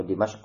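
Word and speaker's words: Dimashq (دمشق): Atau Dimashq ya, bacanya Dimashq dengan kasrah dan fi syarqi Dimashq.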